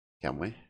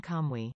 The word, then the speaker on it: Kamuy: Kamuy? That's what I'm looking for. But yes. So Golden Kamuy. Yeah, maybe this is better. – Golden Kamuy. – Kamuy. See, I've been saying it wrong the whole time.